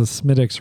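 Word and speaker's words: Smithwick's: So for you people who don't know, you pronounce it Smittix, but if you looked at it, you know, it would say Smith Wicks, and that's like not the right way to say it. It's Smittix.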